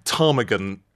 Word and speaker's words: ptarmigan: You know, they had ptarmigan books with a silent P, you know, that didn't work quite so well.